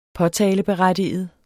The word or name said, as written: påtaleberettiget